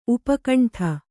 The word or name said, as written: ಉಪಖಂಡ